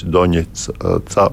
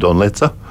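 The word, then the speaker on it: Donecka: Un, un, un citus, kas tomēr ir, tāpat valsts valodas centrs, piemēram, ir pieņēmis to, ka uh, atbilstoši Ukraiņu izrunai būs nevis Doņetca, bet Doņetca, Doņetca bet Donetca.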